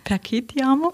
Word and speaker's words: Perché Ti Amo: Wir hören jetzt gleich Ricci e Poveri mit Sara Perci Ti Amo. Habe ich das richtig ausgesprochen? – Perché Ti Amo? – Oh.